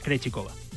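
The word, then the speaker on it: Krejčíková: Y luego en el cuadro femenino, por ejemplo, tenemos el, el Swiatek eh, Ostapenko Kov, Pegula Krejichikova, ¿lo he dicho bien? No, no. Krejcikova. – bueno, Krejcikova.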